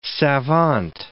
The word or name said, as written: savant